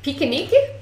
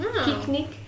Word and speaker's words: piquenique: Right, and I'm not going to pronounce it correctly, but it's... Pikunike? Pikunike? Something like that. P-I-Q-U-E-N-I-Q-U-E. – Oh. – Pikunike?